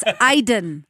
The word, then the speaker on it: Aydin: You can go to my Instagram, which is really simple, Jennifer Aiden, A-Y-D-I-N. Some people spell it D-E-N. Some people spell it A-I-D-A-N. It's A-Y. It's a Turkish last name. It's very common in Turkey. It's kind of like the Smith of the Turkey. So it's Aiden.